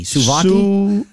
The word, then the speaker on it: souvlaki: Slovaki. Souvlaki? – Souvlaki. – Souvlaki. – Souvlaki. – Yeah. – V-L-A-K-I. – Souvlaki. – Really? – Yeah. – No. Seriously? – No. – I thought you might. Souvlaki.